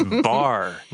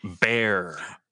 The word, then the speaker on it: Bar: It's bar, not bear. – Oh, so I should put pants on. – And just don't stand up. Just the E is silent.